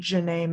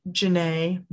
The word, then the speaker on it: Jenay: And then personal Instagram is Jazzy Jenay May at Jazzy Jenay May, M-A-Y. That's my middle name. – And Jenay is spelled J-E-N-A-Y, right? – Yes.